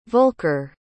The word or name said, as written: volker